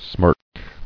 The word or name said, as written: Smirkingly